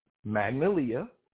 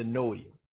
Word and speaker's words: Magnolia: There's two other pronunciations. There's Magnolia and the Noia. They don't even pronounce like the whole first three letters.